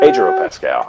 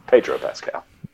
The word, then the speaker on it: Pedro Pascal: Pedro Pascal. I said it wrong. Pedro Pascal.